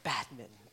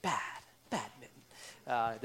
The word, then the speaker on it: badminton: It's not badminton, like you're correcting some strange kitten named Mitten. You know, badminton, bad, badminton. There's an N that's snuck in there, although that's not typically how people pronounce it.